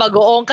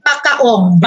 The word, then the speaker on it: bagoong: Kaya ba nilang sabihin bagoong? Kasi bagoong, you know. – Their pronunciation is ano? Pakaong.